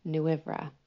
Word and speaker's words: Nwyfre: It's spelled N-W-Y-F-R-E, and it's pronounced Nwyfra.